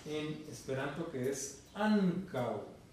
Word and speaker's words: ankaŭ: O sea, la pronunciación en Esperanto, el acento se expresa en la penúltima vocal. La palabra ankaŭ, ¿cuántas vocales tiene? Tiene dos vocales, porque la final es uo, que no es vocal.